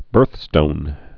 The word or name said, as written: birthstone